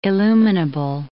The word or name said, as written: illuminable